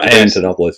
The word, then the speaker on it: Antonopoulos: Atopoulos? How do you pronounce that? – Antonopoulos, yeah, Andreas, yeah.